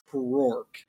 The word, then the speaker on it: Krork: Excuse me, it's pronounced Krork. K-R-O-R-K.